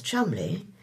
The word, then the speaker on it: Cholmondeley: It would be very interesting to hear how the appointments department computer copes with surnames like Featherstone Hoare, correctly pronounced Fanshawe, and Cholmondeley, correctly pronounced as Chumley, or first names like Sian, correctly pronounced Sean.